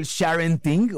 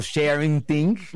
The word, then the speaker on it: sharenting: Bueno, antes redefinamos esto del sharenting o sharenting. ¿Cómo se pronuncia correctamente? – Sharenting. – Gracias. Sharenting.